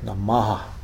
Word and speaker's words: Namah: Namo Namah, even though it's transcribed as Namah, it's pronounced, I think, more like Namaha. There's a quick A after the H.